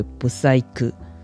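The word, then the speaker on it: 舞祭組: 以中文来讲，听起来好像没什么，但它的日文发音为不塞科。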